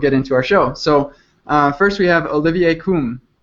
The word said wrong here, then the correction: Combe: Is it Koum? – No. – Is it Kong? – Okay, listen carefully. It's Olivier Combe. – Olivier Combe.